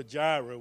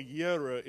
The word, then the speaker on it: Jireh: And Jehovah Jireh, we say. Jehovah Yireh is the correct pronunciation in Hebrew.